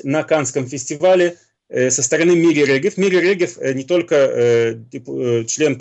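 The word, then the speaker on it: Мири Регев (מירי רגב): Я видел совершенно очаровательную фотографию члена Ликуда Мири Регев, по-моему, так произносится ее имя? – Да.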